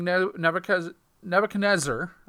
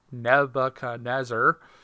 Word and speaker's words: Nebuchadnezzar: To King Nebuchadnezzar. I can never say that right. Nebuchadnezzar. And he ordered the soldiers to throw Shadrach, Meshach, and Abednego into a fiery furnace.